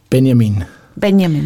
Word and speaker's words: Benjamin: Is it Benjamin or Benjamin? – You could pronounce it both ways. – And how do you do it? – Benjamin. It's the German way to say it.